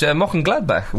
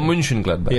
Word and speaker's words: Mönchengladbach: Mönchengladbach went to Leverkusen and won 6-3. – Good pronunciation there. – Yeah, thanks. Or Mönchengladbach. – I would say Mönchengladbach.